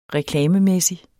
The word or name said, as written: reklamemæssig